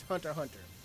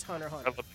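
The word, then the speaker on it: Hunter x Hunter: No, no, the X is silent. It's Hunter Hunter.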